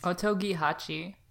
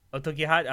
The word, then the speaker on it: OTOGI8: It's OTOGI8. It's like the number 8. – Okay. – I don't know. – Yeet. – Otogihachi. – Otogihachi. Oh, that makes sense.